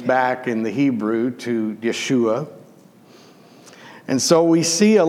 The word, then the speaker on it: Yeshua: And Joshua, then, this man whose name is pronounced Yeshua, which